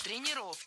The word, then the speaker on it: тренеров: Тренеров неверно. Перед нами, опять же, слово с неподвижным ударением. И двигать это ударение мы не должны. Всегда ударяем «е» в первом слоге.